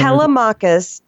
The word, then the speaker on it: Telemachus: Telemachus, T-E-L-E-M-A-C-H-U-S.